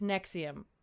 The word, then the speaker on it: NXIVM: Very important detail here. It's spelled NXIVM. – Yeah, they really went there. – But it's Nexium, which is also a heartburn medication, I think.